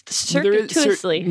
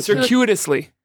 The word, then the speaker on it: circuitously: Circuitously. – Hold on. Let's get this right. There's a way to do it. circuitously. – Say it again. – Circuitously. – That's it.